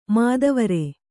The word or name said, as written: ಮದವಾರಿ